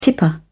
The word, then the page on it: tipper: British English